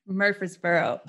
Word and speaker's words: Murfreesboro: Murfreesboro. – It's got to say it right. – Yeah. It's got to sound like you have mashed potatoes in your mouth.